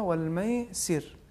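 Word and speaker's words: والميسر: اس کی ایک مثال آیت نمبر 219 میں ہے, دیکھیے ان الخمر والمیسر, یہاں پہ اگر ہم وقف کریں تو ہو جائے گا والمیسر, یعنی ریک کا جو زیر ہے اس کا تلفظ نہیں ہونا چاہیے.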